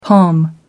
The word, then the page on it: pom: American English